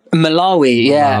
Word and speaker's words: Malawi: Malawi. How do you say it? – Malawi.